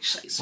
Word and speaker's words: Shiest: It's like, Shiest Bubbz, right? And I'm going to spell it like that. S-H-I-E-S-T.